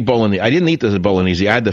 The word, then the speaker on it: bolognese: The silent G. People say bolognese.